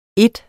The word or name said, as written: ene